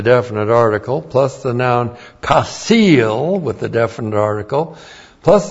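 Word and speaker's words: chasil: C-H-A-S, long I-L, kasil. All right, a couple of things on the kasil.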